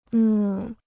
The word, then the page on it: 呢: ne